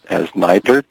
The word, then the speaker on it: NITRD: The Networking and Information Technology Research and Development Program, or NITRD, pronounced here in Washington as NITRD, is the primary mechanism the federal government uses to coordinate its investments in networking and information technology research.